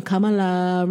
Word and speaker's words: Kamala: Kamala, rhymes with Pamela.